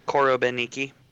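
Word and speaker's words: Korobeiniki: Korobeniki.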